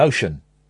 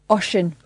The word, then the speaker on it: Oisin: So, how would you say this name? O-I-S-I-N. Ocean. – Ocean.